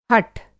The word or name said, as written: हठ